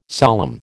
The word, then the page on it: solemn: American English